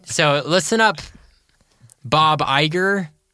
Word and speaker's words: Iger: Yeah, Bob Iger.